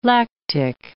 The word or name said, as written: lactic